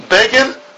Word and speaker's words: Begin: And they wrote about him, Begin rhymes with Fagin.